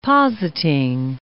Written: positing

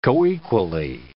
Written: coequally

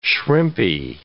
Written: shrimpy

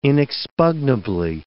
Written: inexpugnably

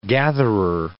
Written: gatherer

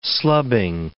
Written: slubbing

